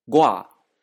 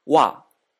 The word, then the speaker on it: 我: 私、ゴア、ゴア、えただし、えほとんどの場合は、わ、わと発音されます。